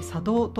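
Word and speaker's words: 茶頭: この堺の商人たちの中で茶の湯を取り仕切る人、茶道、茶の頭と書いて茶道と読みますけれども、この茶道、茶人が多く誕生しまして、で商人ですから外国との貿易もしています。でそういったことで財を成して、で外国との取引で茶道具とか美術品も集めていきましたよね。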